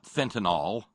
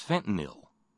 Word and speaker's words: fentanyl: It's not fentanyl. It's fentanyl. N-Y-L with the Y pronounced as a short I.